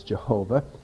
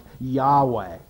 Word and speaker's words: Yahweh: because that is almost certainly an incorrect pronunciation. Most likely, at least if we were to think in terms of modern Hebrew, the pronunciation of YHWH would be something like Yahweh.